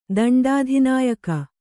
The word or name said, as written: ದಂಡಾಧಿನಾಯಕ